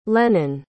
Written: lennon

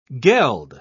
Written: geld

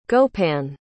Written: gopan

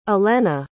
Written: ellena

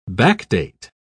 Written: backdate

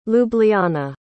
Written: ljubljana

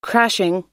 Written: crashing

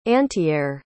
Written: antiair

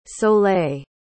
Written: soleil

